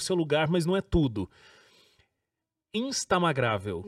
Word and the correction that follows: Instagramável: Não. – Instagramável. – É. – Gente, não sai a palavra. Falei certo? – Instagramável. – Instagramável. Isso. Saiu.